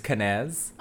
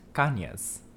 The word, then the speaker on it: Cañez: So I grew up with the white way of saying my name, which is Cañez, which I guess my dad adopted when he went into the military. And then it wasn't until I was like older that I found out it's correctly pronounced Cañez. – Cañez. Ooh. – Because it has that N-Y. – Okay. – That N comes with a tilde.